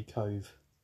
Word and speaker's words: Kohv: K-O-H-V. It's pronounced apparently Cove.